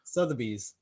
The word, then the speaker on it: Sotheby's: I think it's sloth slothby. Um, slothbees. – What is it? Sotheby's. Sotheby's. Okay, there you go.